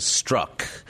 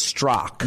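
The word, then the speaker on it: Straka: Well, yeah, that's the thing is once I explain to people that the last A is silent, they sort of switch it suddenly to Struck. And no, it's not Struck, it's Strock.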